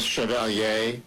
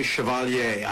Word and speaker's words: Chevalier: And I pronounce it the French way, Chevalier. I don't know if it's Chevalier, like the way, you know, I've been thinking about Chevrolet.